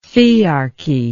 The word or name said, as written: thearchy